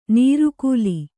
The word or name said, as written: ನಾರಿಕೇಲಿ